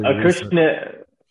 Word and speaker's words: Acushnet: Acushnet, yep. – Are we saying that correctly? Because we were saying it before you came on. Okay. – Yeah. We tried to do a little Acushnet.